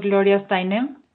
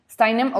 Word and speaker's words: Gloria Steinem: No, yo lo pronuncio Gloria Steinem. – Steinem, ok, es que yo no sé cómo pronunciarlo.